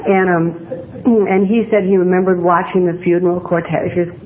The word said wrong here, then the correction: cortege: what do you say, going to, hi there, Tom. Mr. Pronounce that word. The cortege, thank you.